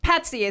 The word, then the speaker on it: Patzi: Promo code PATSY, P-A-T-Z-I. – This is the Tantalk Radio Network. – This is Patsy with the Z, and I am back with my guest in the studio, Rita Moreau. And I just want to remind you of two things. First of all, Patsy is spelled P-A-T-Z-I, and that's the promo code for MyPillow.